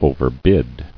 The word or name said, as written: Overbid